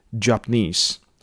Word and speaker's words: Japanese: 啊，如果大家有，比如说有时候看一些纪录片，比如说那个 National Geographic 或者是 Discovery 的话，在讲二次大战啊，或者是说反正日本的一些工业非常厉害的，大概都会念成 Japanese。好，那个重音呢，稍微给大家稍微注意一下下。